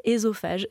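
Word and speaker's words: œsophage: Donc le « e » dans l'eau, quand c'est suivi par une consonne, se prononce « é ». On devrait dire « estrogène »,« ésophage », etc.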